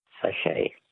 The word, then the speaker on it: sachet: Sachet. – A sachet. S-A-C-H-E-T. Well, they were too easy for you, so we're not asking you any more words that end with a silent T, mainly because there are no more questions in that category.